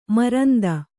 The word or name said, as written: ಮರಂದ